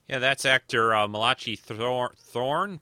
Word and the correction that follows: Throne: Throne, Throne, I think is how you say his last name, playing uh, Commodore Mendez here.